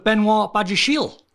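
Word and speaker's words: Benoît Badiashile: So Chelsea linked with Benoit Badiashile.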